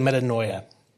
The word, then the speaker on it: metanoia: It's actually metanoia.